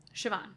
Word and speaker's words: Siobhan: I thought Saya she was Pan. So, I know. I was like, now how in the world? I was waiting so patiently. I was like, say it, please. Someone say her name. – Yeah. – And then finally, Siobhan. I was like, okay, thank God. That was my guess, though.